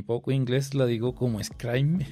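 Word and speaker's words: Scribe: Pronuncia Scrime.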